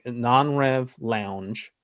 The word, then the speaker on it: Non-Rev Lounge: So everyone who's listening, go to make sure I'm saying this right, Doug. Non-Rev Lounge, the Non-Rev Lounge. – Yep.